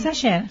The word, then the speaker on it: Sacher: ¿Cómo se pronuncia? No lo sé. – Sacher.